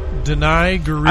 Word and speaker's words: Danai Gurira: Danai Gurira.